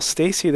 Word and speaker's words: Stacie: Stacy is spelled S-T-A-C-I-E, and court is spelled C-O-U-R-T, just like basketball. – All right, perfect. Well, Stacy, thank you once again for joining me on the show today.